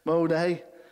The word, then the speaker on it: Monet: So they had things like Picasso, Van Gogh, Monet, is that right? How do you say it? Monet.